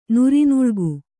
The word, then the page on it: ನುರಿನುಳ್ಗು: Kannada